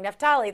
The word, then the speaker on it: Naftali: Naftali, there you go, Bennett, in leadership.